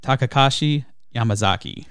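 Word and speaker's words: Takashi Yamazaki: It's pronounced Takakashi Yamazaki.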